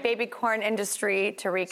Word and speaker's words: Quorn: This is corn with a Q, but it's pronounced corn.